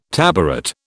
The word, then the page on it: tabaret: British English